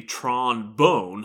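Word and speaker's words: Tron Bonne: Interestingly, in Japan, the names are pronounced with a hard O, so Tron Bon is actually Tron Bone, like the musical instrument trombone. And Teasel Bon is actually Teasel Bone, or T-Bone for short.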